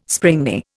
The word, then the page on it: springy: Indian